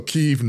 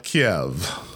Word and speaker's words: Kyiv: For those asking, NPR goes with the Ukrainian spelling and pronunciation, not Russian, wherever possible, when reporting on the Ukraine. So Kyiv, not Kiev.